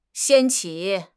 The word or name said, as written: 掀起